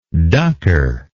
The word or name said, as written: ducker